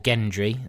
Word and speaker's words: Gendry: And and when Gendry appears, or Gendry. – It's a hard G.